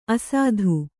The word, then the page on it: ಅಸಾಧು: Kannada